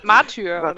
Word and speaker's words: Martyr: Und unter anderem ist da auch Warhammer 40k Matter. – Was? – Ich weiß nicht, wie es ausgesprochen wird. Matter. – Martyr. – Martyr. – Achso.